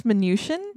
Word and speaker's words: Mnuchin: In 1990, the property was acquired by Washington residents Adriana and Robert Munchin. Am I saying this right? – Munchin? I think it's Mnuchin.